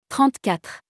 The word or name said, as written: 34